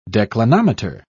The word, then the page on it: declinometer: British English